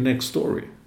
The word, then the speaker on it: Nextory: Nextory kan det vara, men tar man ett X så blir det, det blir inte så, men uttalar man det så blir det Nextory.